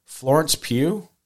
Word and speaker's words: Florence Pugh: Florence Pugh, is that right? – Yeah.